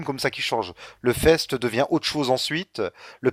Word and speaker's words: FEAST: Je crois que ça se prononce FEST, du coup. – Le FEST, oui, je sais plus, F-E-A-S-T. – Ouais, je crois que ça se prononce, sinon ça fait point, FIST.